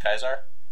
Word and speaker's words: Caesar: So weird that Caesar was there. – Kaiser, yeah. – Kaiser? – Well, some people pronounce it Kaiser, some people pronounce it Caesar.